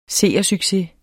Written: seersucces

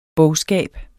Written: bogskab

